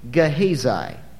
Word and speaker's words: Gehazi: Now, that's the way it's pronounced to the best of my ability. I've checked that out. And there are probably other ways to pronounce it. That's not the way you would pronounce it in Hebrew, but I think in English that's probably close enough, all right? G-E-H-A-Z-I, Gehazi, not one of your more famous Old Testament characters.